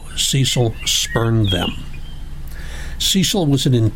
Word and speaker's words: Cecil: His family pronounced his name Cecil in the American fashion.